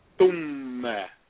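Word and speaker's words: Thumma (ثُمَّ): Next we have the sequential then, which is pronounced Thumma. Listen very carefully. Thu with a Dhamma, followed by a Meem with a Shedda Fatha, gives us the sound Thumma.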